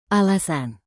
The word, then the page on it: alasan: indonesian